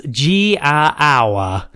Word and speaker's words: Giaour: It's G-I-A-O-U-R. – Oh, God, I don't know. – Yeah, I did look up a pronunciation, and I've written it as G-A-Hour. – Okay. – G-A-Hour, which is awful to say.